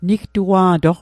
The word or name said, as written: nigtua'toq